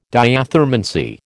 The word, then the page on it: diathermancy: American English